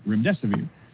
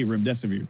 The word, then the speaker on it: Remdesivir: Because when they would try to repeat it back to me, the hosts, they couldn't say Remdesivir. So I told them, just remember, it rhymes with Run, Death is Near.